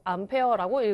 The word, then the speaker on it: 암페어: 단위는 A라고 쓰고 암페어라고 읽을 수 있습니다.